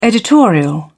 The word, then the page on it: editorial: British English